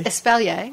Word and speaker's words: espalier: When you grow vertically, like in the small yards in New Orleans, espalier? – Espalier. – I said it right. – Yes, espalier.